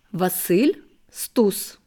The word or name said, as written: Wassyl Stus